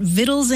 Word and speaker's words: victuals: And you'll also see it spelled V-I-C-T-U-A-L-S, victuals. Mm-hmm. And you look it up in the dictionary and the pronunciation is victuals for both words.